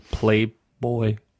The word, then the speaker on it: Playboy: Nein, ich habe es schlecht ausgesprochen. Playboy. – Ah, okay. – Muss ich sein Englisch aussprechen.